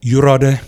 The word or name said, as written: Yurawde